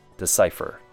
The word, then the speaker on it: decipher: Three syllables. First syllable is de, which has a short U sound. Then sigh, like the word sigh, like a big sigh. And then fer, sounds like F-E-R, fer, decipher.